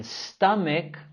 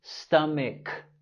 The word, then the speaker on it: stomach: Las letras CH en stomach más bien pronunciamos como el sonido K, K. Stomach, K.